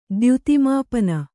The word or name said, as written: ದ್ಯುತಿಮಾಪನ